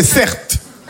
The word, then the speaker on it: Certe: Mon prénom, c'est Certe. Ça s'écrit C-E-R-T-E.